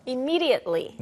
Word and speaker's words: immediately: Immediately. – 네, 원래 이제 T 발음도 마지막에 해주는 게 맞는데 yeah. 잘 안 해요.